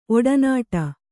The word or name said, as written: ಒಡನಾಡಿ